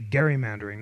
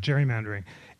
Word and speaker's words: gerrymandering: But in the face of protest from anti-federalists, including Elbridge Gerry of Massachusetts, I've learned to pronounce it pedantically gerrymandering, not gerrymandering, and Edmund Randolph of Virginia,